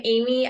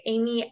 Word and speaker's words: Aymie: So I am Aymie. I go by Aymie Alexandra on all platforms. But like we were just joking, my name is spelled A-Y-M-I-E, which is different.